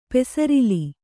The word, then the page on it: ಪೂಸರಲು: Kannada